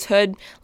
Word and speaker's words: Houde: People always say my last name wrong, and I've kind of stopped correcting them, but it is Hood, like the hood of a jacket.